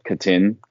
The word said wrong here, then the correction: Caden: Hauser. – Caden. – Ah, see, there you go. Caden Hauser.